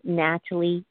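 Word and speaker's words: Nathalie: I actually have Facebook, which is Christina Natalie Smith. And Natalie is spelled with a N-A-T-H-A-L-I-E.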